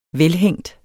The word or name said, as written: velhængt